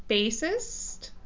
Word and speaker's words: bassist: He was a Sex Pistols bassist. Is that how you say it? Bassist? – Yes.